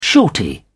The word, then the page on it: shorty: British English